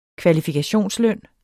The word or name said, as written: kvalifikationsløn